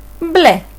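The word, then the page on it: blue: Greek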